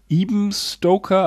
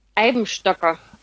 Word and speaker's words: Eibenstocker: Das ist die Firma Ebenstoker, aber ich glaube, das spricht man anders aus. Und genau, das heißt, ja. – Ja, wie heißt das nämlich richtig? – Eibenstocker. – Eibenstocker.